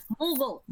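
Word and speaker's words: Moogle: Chocobo and Mowgli. That little whatever. – Mughal. That, it's pronounced Mughal.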